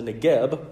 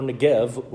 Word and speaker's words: Negev: The southern portion of the land is called, depending on who you ask, the Negev with a B or Negev with a V. Depends on how you pronounce that Hebrew letter.